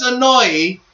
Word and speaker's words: Anoa'i: All right, Lance Anoa'i is that's so funny that his name is pronounced that way because it almost sounds like annoying, which is almost annoying as well.